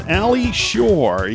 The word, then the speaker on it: Ali Tschuor: My guest on Central Indiana today is Ali Tschuor.